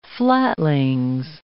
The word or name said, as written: flatlings